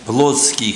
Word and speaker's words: плотский: Мы немножко сказали семя плотский христианин. Вот раньше было в словарях плотской, а теперь все почему-то делают ударение плотский христианин. Ну пусть так будет.